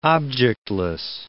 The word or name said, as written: objectless